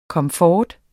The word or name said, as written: komfort